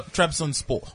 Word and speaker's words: Trabzonspor: So Trabzonspor.